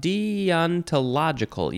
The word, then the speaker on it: deontological: Kind of deonta, deontological. Yep, that's how you say that.